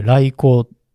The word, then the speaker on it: 来迎: 迎えに来ると書いて来光と読むんですけど、